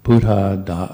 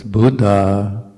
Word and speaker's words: Buddha: Try to pronounce both letters of a double consonant. That's also very English. In English, we don't do that, or usually don't do that. So it's Buddha, or Sabba, or Satta. So there's a little, tiny little space between the D's and the B's here and the T's.